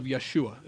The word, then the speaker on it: Yeshua: because there's no J sound in the Hebrew language, it's Yah. So, son of Yeshua is a better way to pronounce that.